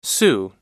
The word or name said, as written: sioux